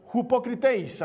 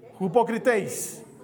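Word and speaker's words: hypocriteis (ὑποκριτής): De hecho, eh, la, Biblia utiliza un término en griego que, se pronuncia hypocriteis. A ver, digo conmigo. Hupocriteis.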